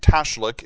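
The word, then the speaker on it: tashlich: Scott Cohen, 14 of Hollidaysburg, finds, and I think tashlik is the way to pronounce that, or throwing bread into moving water, a freeing symbolic experience.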